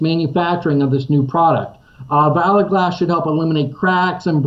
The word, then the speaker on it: Valor Glass: Valor Glass, okay.